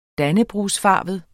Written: dannebrogsfarvet

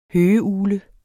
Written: høgeugle

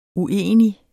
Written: uenig

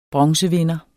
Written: bronzevinder

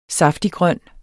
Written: saftiggrøn

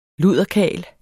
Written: luderkarl

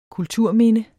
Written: kulturminde

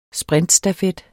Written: sprintstafet

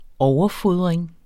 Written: overfodring

